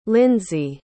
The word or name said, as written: lyndsie